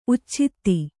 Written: ಉಚ್ಛಿತ್ತಿ

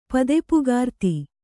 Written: ಪದಪುಗಾರ್ತಿ

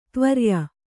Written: ತ್ವರ್ಯ

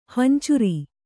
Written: ಹಿಂಚಾರಿ

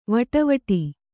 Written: ವಡವಡ

